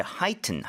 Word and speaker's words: heighten: 그리고 발음할 때 지금 쌤의 발음을 들어보니까 원래는 heighten이잖아요. Mm-hmm. Right? 근데 heighten 하는데 앞쪽에 accent가 너무 많이 가니까 튼 하는 T 사운드가 거의 안 난 것 같아요. – Heighten.